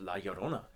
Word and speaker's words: La Llorona: Of La Llorona from Mexico, the woman in white.